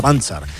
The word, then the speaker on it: Panzer: Además, ayer se despidió en Bilbao Basket Melvin Panzer, se pronuncia Panzer.